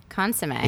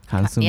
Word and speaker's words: consommé: Consommé. – Is that how you say it? Consommé.